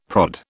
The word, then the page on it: prod: British English